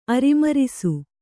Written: ಅರಿಮರಿಸು

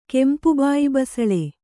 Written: ಕೆಂಪುಬಾಯಿಬಸಳೆ

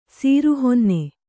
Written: ಸುರಹೊನ್ನೆ

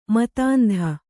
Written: ಮತಾಂಧ

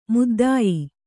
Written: ಮುದ್ದಾಯಿ

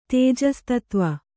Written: ತೇಜಸ್ತತ್ವ

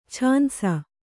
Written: ಛಾನ್ಸ

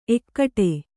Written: ಏಕಟ

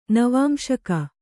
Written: ನವಾಂಶಕ

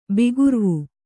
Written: ಬಿಗುರ್ವು

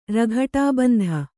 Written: ರಘಟಾಬಂಧ

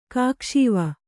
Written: ಕಾಕುಸೇವೆ

